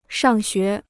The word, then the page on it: 上学: shàng xué